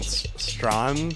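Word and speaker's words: Strachan: Up next, we have Malcolm Strawn, because I was corrected. You don't pronounce the CH, apparently. – CH is silent.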